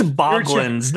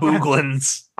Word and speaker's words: Boglins: It's Boglins, not Booglins.